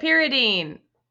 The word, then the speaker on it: phenazopyridine: Phenazopyridine.